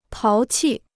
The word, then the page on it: 淘气: táo qì